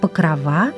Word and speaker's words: Покрова: Обратим внимание на то, что в сочетании праздник Покрова мы ставим ударение на окончание Покрова.